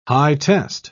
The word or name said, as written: high-test